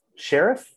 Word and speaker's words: Sheriff: They lost two to one to Sharif. Sheriff. It's spelled like sheriff.